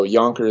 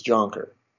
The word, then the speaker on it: Jonker: My dad was born in the Netherlands, and so Yonker is a Dutch name. And when he was a kid, he immigrated to Canada. And once they got to Canada, everyone pronounced it Jonker, and that's how they pronounced it. And so when I was a kid, I was Jonker.